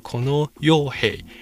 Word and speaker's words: 河野洋平: 那么河野洋平的发音，日文的发音叫做 kono yohei，跟江的佣兵的那个发音是一模一样的，所以就有人就做了这样的一个戏谑的嘲讽就是了。